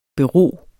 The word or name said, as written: BEROEDE